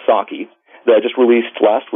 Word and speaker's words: psake: So, it's pronounced Saki, P-S-A-K-E. It's a silent P.